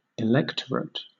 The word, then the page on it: electorate: British English